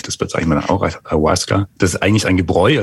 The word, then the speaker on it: Ayahuasca: Was ist das Ayahuasca und habe ich es richtig ausgesprochen? – Ja, hast du richtig ausgesprochen, Ayahuasca.